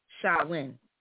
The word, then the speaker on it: Samhain: It's a Gaelic word. And it's supposed to be Samhain. It's S-A-M, but it's pronounced Samhain.